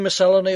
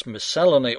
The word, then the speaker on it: miscellany: The rebuttal is to an impertinent listener who emailed in to tell me that I pronounce miscellany all wrong. I'm putting the accent on the wrong syllable, this patrolman of the pronunciation police wants me to know. He wants me to say miscellany, which sounds to me like a character in some Tennessee Williams play.